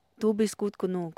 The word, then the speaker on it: Du bist gut genug: Du bist gut genug. – Okay. I kind of had a feeling that I was coming. – I said it right? – Yeah, you said it perfectly.